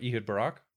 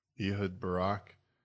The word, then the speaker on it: Ehud Barak: What, Ehud Barak? – Ehud Barak.